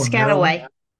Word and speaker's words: Piscataway: Now, 1852, John purchased 187 acres at the intersection of Marlborough Piscataway. I butchered that, and I apologize for people. – Piscataway. – Piscataway? – Yep.